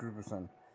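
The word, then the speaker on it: Gudbranson: Gudbrandsson? – Yeah, Eric Gudbrandsson.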